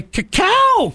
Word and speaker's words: cacao: Cacao. Isn't that how you pronounce it? – It is.